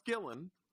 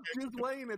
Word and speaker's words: Ghislaine: It's not Ghislaine. It's pronounced Gillen.